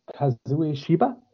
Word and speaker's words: Kazue Shiba: It was written by a woman named Kazue Shiba did I pronounce that right? Yeah, pretty much.